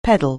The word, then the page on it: peddle: anglais